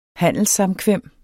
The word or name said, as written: handelssamkvem